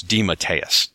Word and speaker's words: DeMatteis: And yes, that is apparently how you pronounce his last name. I asked him on Twitter. It's DeMatteis.